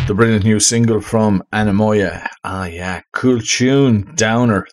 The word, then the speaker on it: Anemoia: And it's how you pronounce it is Anamoya, but how you spell it, A-N-E-M-O-I-A. Yes, that's how you spell it. And then it's on Instagram, spelled another way, A-N-E-M-O-Y-E-A-H.